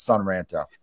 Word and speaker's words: Son Ranto: I always want to say San because it rhymes with Ron, but I know it's SUNRANTO.